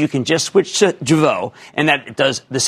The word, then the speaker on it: Jeuveau: It's a medical aesthetics play that just launched their first product, which is Jeuveau, which rhymes with hello.